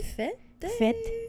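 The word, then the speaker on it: fête: Oh, guys, I don't know how to say this word. F-E-T-E, but there's, like, an accent above the first E. – Fete. – But it's over the first one, so it'd be like, Fete.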